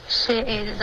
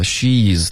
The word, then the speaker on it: she's: She is the smartest of the Tribiani children。 – 没有连读，你居然把 she's 的发音分开了，发成 she is，记得你要按照这个实际发音的音标，OK，she's the，OK，不然你这样读呢，对方是能听懂的，可是他们肯定不会这样说给你听的，会影响到你的听力。